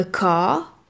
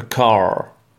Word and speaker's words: car: D'ailleurs, en anglais, parfois, donc le R, comme on l'a dit, ne se prononce pas. Par exemple, pour le mot voiture, on dira a car. – A car.